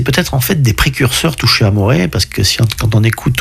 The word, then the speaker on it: Touché Amoré: Alors c'est, ça se prononce en français, il y a des accents sur les E, donc c'est Touché Amoré.